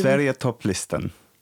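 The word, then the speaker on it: Sverigetopplistan: This is the name of it in Sweden is the Sveriga Topplistan, I believe. – Sveriga Topplistan. – Sveriga Topplistan. Okay, you could have just said that.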